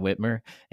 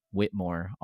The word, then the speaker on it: Whitmer: I'm not getting into politics, but nonetheless, people mispronounce her name Whitmore all the time. So I think it would be a lot of fun to just confuse the shit out of people to have Cam Whitmore, and then Governor Whitmer. Everybody pronounces incorrectly Whitmore.